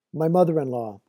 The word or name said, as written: my mother in law